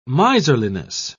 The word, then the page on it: miserliness: British English